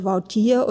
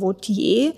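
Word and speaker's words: Wautier: Viele ihrer Bilder hat sie dann allerdings mit Michaelina signiert und auch ihr Nachname wird immer wieder unterschiedlich geschrieben, auch unterschiedlich ausgesprochen, entweder französisch oder niederländisch, also entweder Vautier oder Vautier.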